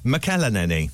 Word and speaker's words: McElhenney: And it's Rob McElhenney.